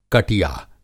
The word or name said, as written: कटिया